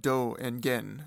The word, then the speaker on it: Dogen: Zen Master Dogen is often referred to within Chinese sources as Dao Yuan Chan Shi, with the Japanese pronunciation of Do and Gen, literally translating to Way Originator or Way Essence.